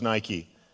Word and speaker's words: Nike: They took a good word when they chose Nike. It's pronounced in the Greek Nike, but it means to conquer, to overcome, to be victorious.